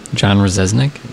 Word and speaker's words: Rzeznik: John Rzeznik.